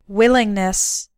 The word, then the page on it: willingness: American English